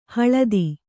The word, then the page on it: ಹಳದಿ: haḷadi